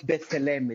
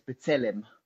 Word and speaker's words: B'Tselem: Only that uh, it's not Betzalem, it's B'Tselem.